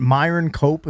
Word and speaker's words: Myron Cope: Didn't the broadcast the long time, is it Myron Cope?